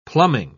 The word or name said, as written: plumbing